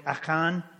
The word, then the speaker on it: Achan: The pronunciation of Achan is more like Achan, but it doesn't really fit with American pronunciation, so I'm going to probably go back to our Americanization of Achan here.